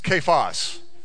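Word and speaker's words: Cephas: How do you say that? Right? It's kephos. There you go.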